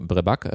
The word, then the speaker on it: Vrevak: Schreibt sich dann VREVAK, also Brebak ausgesprochen.